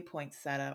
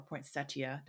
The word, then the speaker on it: poinsettia: You know, every year on Christmas time, you see those lovely poinsettia or poinsettia, depending on your pronunciation preference,